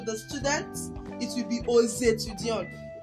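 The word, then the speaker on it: aux étudiants: You will notice a liaison in the Ozetudion as I pronounce the X as Z. I pronounce letter X as Z because the word that follows it is a vowel.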